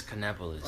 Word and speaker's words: Kannapolis: It's Kannapolis. – Like Indianapolis? I can't. – Well, yeah, it's Kannapolis.